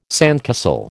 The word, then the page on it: sandcastle: American English